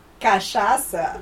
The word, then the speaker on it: cachaça: Cachaça or gin and tonic? – I don't know.